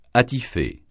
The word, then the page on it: attifer: francais